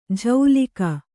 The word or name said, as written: ಝೌಲಿಕ